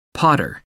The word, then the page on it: pater: American English